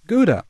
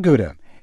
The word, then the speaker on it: Gouda: the proper way to pronounce Gouda. It is not Gouda. It is Gouda. Add as much guttural as you like to the front."